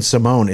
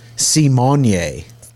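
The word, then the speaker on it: Symoné: But she says it's not Raven Simone. It's Raven Simonye. – What? – S-E-E-M-O-N-Y-E. Simonye.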